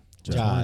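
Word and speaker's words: Jaa: Tony Ya, or Ja? – Ja, it's Ja. – Tony Ja. – That one's Ja.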